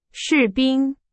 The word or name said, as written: 士兵